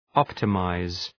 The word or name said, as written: optimize